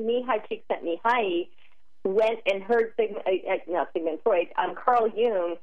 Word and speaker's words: Csikszentmihalyi: Mihaly Csikszentmihalyi.